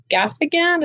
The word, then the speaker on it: Gaffigan: I like Jim Gaffigan.